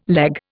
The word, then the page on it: leg: Indian